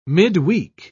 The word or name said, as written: midweek